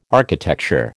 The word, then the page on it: architecture: American English